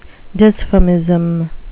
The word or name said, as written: dysphemism